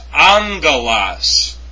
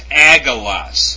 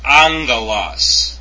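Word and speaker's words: angelos (ἄγγελος): Angel is pronounced angelos, not agelos. Okay, so go back to the Greek form where it reads alpha, gamma, gamma, epsilon, lambda, omega, sigma. And it's not pronounced agalos, it's pronounced angalos.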